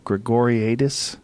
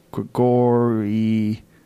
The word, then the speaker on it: Gregoriadis: How do you say Nick's last name? Gregoriadis. Gregoriadis. Nick Gregoriadis. Anyway, real good jiu-jitsu guy.